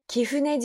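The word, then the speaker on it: 貴船: 这个呃神社呢叫做 Kifune 神社，Kifune 神社读作呃写成汉字写成尊贵的贵，然后船舶的船。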